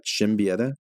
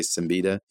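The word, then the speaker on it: Siembieda: Yeah, I think the proper pronunciation might be like Shimbieta, but yeah, it's I say Simbita, and that's usually what he says to people that he's talking to.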